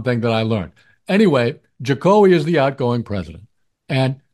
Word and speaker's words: Jokowi: So I always thought that his cool portmanteau nickname was pronounced Jokowi. – Oh, but what is it? Jokowi? – Actually, it's Jokowi.